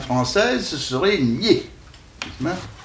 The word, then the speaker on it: Nied: Qui s'écrivent N-I-E-D, mais qui ne sont en pays de langue française et les gens du pays disent nier. Mais, alors, ils vous disent même, vous prononcez ça comme pied.